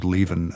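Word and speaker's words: Leven: Jackie Levine, is that how I pronounce it? – Jackie Levin. – Levin, sorry. – Levin.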